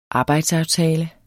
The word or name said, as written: arbejdsaftale